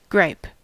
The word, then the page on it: gripe: American English